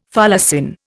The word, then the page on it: folacin: Indian